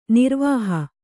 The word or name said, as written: ನರವಾಹ